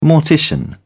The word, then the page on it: mortician: British English